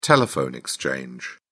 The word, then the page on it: telephone exchange: British English